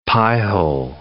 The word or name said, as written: piehole